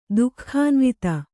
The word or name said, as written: ದುಃಖಾನ್ವಿತೆ